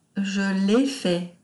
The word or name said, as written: je lai fait